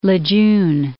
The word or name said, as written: Lejeune